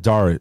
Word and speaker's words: Dare: Is it Dari? D A R E. Yeah. All right, Dari.